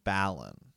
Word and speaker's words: Balon: Martin, he calls him Balon.